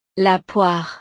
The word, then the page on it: la poire: frances